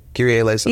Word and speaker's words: Kyrie Eleison: Yeah, Kyrie Eleison.